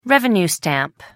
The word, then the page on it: revenue stamp: American English